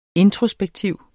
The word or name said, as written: introspektiv